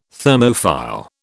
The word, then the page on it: Thermophile: British English